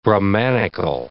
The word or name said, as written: Brahmanical